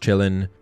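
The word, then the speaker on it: Qilin: Number one, it's pronounced chillin, but it's actually spelt with a Q Q I L I N.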